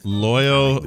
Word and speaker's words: Loyo: Loyo rhymes with Froyo, and I like that.